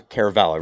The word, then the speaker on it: Caravela: Okay, so Caravela, right? That's how is that the Italian pronunciation, by the way? – It is. Well, it's actually Caravela is a Portuguese name.